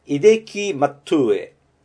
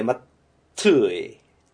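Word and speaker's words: Hideki Matsui: 秀樹松井は H を発音しません。イデキ・マットゥエ。イデキ・マットゥエ。松井じゃなくて、マットゥエ。トゥエですね。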